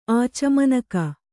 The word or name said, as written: ಆಚಮನಕ